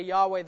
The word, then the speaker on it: Yahweh: Every verb, this one is, and it happened that the word of Yahweh or Jehovah, same name, I'll frequently say Yahweh, that's the Hebrew pronunciation.